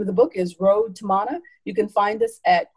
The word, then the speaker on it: Road to Mana: Well, I don't want us to leave without people finding out how they can get a wonderful copy of your book, Road to Mana. Did I say it right? – Yes, you said it perfectly.